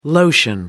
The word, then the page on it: lotion: American English